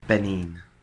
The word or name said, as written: Benin